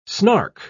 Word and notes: snark: British English

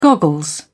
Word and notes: goggles: British English

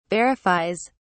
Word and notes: verifies: British English

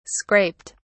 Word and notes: scraped: British English

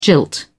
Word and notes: jilt: British English

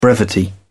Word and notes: brevity: British English

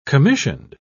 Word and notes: commissioned: British English

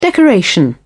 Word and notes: decoration: British English

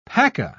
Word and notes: paca: British English

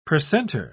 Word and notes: precentor: British English

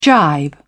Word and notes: gibe: British English

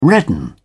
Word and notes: redden: British English

